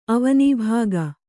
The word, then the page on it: ಅವನೀಭಾಗ: kannada